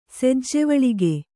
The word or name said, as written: ಸೆಜ್ಜೆವಳಿಗೆ